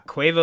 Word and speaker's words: Quavo: Is that how you say it? Quavo? – Yeah, Quavo.